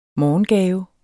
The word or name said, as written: morgengave